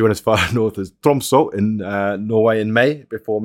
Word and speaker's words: Tromsø: So she went as far north as Tromsø. – Great pronunciation. – Good accent. – Yeah. – Tromsø.